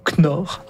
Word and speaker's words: Knorr: Alors, je suis pas exactement sûr comment on prononce K-N-O-R-R, mais on va dire Knorr. Pas de K.